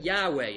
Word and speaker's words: Yahweh: We can't be sure exactly how the word was to be pronounced, but Yahweh is probably as close as we can get.